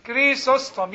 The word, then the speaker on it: Chrysostom: CH. Yes, it sounds like a K. You are close. Chrysostom, yes, that's right.